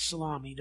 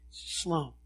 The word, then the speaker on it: Salome: Some of you, I know, you've looked at that word and pronounced it salami. Don't do that, okay? Salome.